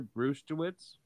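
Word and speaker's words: Brzustewicz: So we got Andre Kuzmenko, Yoni Yermo, Hunter Brustewitz, I believe is how you pronounce that. I could be wrong.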